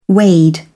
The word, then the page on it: wade: British English